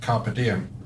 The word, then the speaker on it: Carpe Diem: Well, the Romans would have pronounced it Carpe Diem.